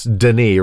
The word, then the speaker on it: Denis: Denny, my little brother, he texted me the words D-E-N-I-S, Denny, right, is how mm-hmm. it's pronounced.